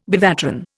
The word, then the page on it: bevatron: Indian